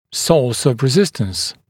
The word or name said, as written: source of resistance